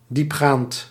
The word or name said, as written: thorough